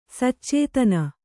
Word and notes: ಸಚೇತನ: sacētana

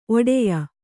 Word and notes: ಒಡೆಯ: Kannada

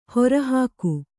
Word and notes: ಹೊರೆಹೊಗು: hore hogu

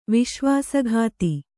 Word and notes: ವಿಶ್ವಾಸಘಾತ: Kannada

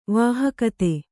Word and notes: ವಾಹಕತೆ: vāhakate

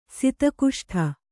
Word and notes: ಸದುಗೋಷ್ಠಿ: kannada

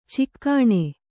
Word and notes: ಚಿಗಣಿ: cigaṇi

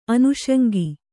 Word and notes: ಅನುಷಂಗ: anuṣaŋga

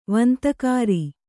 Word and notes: ವಂತಕಾರಿ: vantakāri

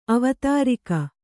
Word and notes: ಅವತಾರಿಕೆ: Kannada

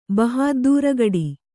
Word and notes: ಬಹಾದ್ದೂರಗಡಿ: Kannada

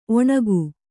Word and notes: ಒಣಿಗೆ: oṇige

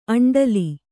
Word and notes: ಅಂಡಲಿ: aṇḍali

